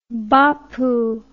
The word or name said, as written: bapu